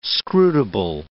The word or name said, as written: scrutable